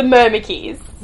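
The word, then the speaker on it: Myrmekes: And so, against the Mimikis? – Yeah. – What Mimikis? – I was like, did I say it right? – Okay. Mimikis! – The giant ants. – Yes, the Myrmekys.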